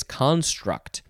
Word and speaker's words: construct: No, this one, the emphasis is on the first syllable, so it is construct.